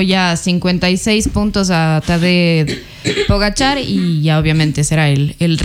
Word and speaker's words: Pogačar: Yo escuchaba al Pogačar, bueno, a ver, vamos a decir Pogačar. – Pogačar, sí.